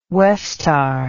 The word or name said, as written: Westar